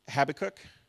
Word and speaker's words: Habakkuk: I love from, we know, I'm going to say, butcher this wrong. I do it every time. I'm just going to pretend like it's an American thing. Habakkuk? Yep. How do we say it? Habakkuk? Habakkuk, I like that one better.